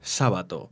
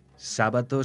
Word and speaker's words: Sábato: Sábato se pronuncia como una palabra esdrújula a pesar de no llevar tilde, por ser apellido italiano, de Cerdeña.